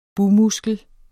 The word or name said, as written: bugmuskel